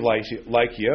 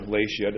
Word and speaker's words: Lycia: And when we had sailed over the Sea of Cilicia and Pamphylia, we came to Myra, a city of Lycia, or Lacia, depending on how you want to pronounce it.